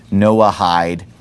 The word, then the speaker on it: Noahide: They're just kind of pronouncing the H on the end of Noah, Noahide.